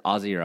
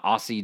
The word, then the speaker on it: Aussie: He says Aussie. – Yeah, with an S. – With the S. – Rather than Aussie. – That's not how it's said, yeah. Yeah. Aussie. Aussie is how an American says it.